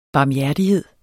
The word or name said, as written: barmhjertighed